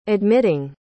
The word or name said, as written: admitting